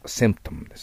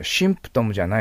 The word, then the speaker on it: symptom: そういう時にセンプトムです。ただ発音がですね、上記の音にしないようにしてください。死じゃないです。背の口で言います。発音記号を見てください。 S だから。そうすると背の口で巣ですからセンプトムと発音します。いいですか？シンプトムじゃないですよ。センプトムです。